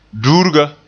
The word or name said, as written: duurga